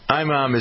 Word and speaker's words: imam: Anyway, so the IMAM, is that how you pronounce it? IMAM, I guess it is. I-M-A-M. – I think it depends. Imam, I've heard.